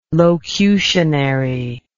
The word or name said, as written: locutionary